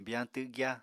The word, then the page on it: biyañ twgya: Apatani